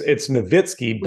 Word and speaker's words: Nowitzki: Like, I'm like, is it Dirk Nowitzki or is it Dirk Nowitzki? – It's Nowitzki, but he just kind of, well, all right, fine, Nowitzki, whatever.